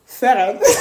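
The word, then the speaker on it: Frieren: Feren. Beyond the Journey. – Exactly, because it's just because you said watch Feren, I was like, that's not. – That's not how you say it, exactly.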